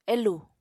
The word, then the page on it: elù: Apatani